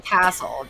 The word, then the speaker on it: Castle: How do you pronounce her last name? Castle? – Jensen Castle, just like a castle in the sky.